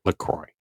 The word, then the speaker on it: LaCroix: Yes, and it's exactly, like, no, the LaCroix website literally says it rhymes with the term enjoy. So you can say enjoy LaCroix.